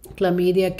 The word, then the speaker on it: chlamydia: So chlamydia together, and so chlamydia is spelled C-H-L-A-M-Y-D-I-A, chlamydia, together with another group called the rickettsias, are very interesting bacteria.